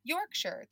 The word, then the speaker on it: Yorkshire: And I said like, oh yeah, like I was interviewing, or we're going to interview a girl from Yorkshire. That's how you say it, right? – Mm-hmm.